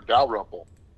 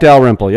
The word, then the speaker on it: Dalrymple: Well, and I can recall a short meeting I was involved with your past governor, Dalrymple. Did I say that right? – You did. Governor Jack Dalrymple.